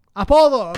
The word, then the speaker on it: apodo: How do you say nickname in Spanish? Apodo. Okay. – Apollo? – I was like, you say Apollo? – I was gonna like shoot. Apodo. Apodo. Okay.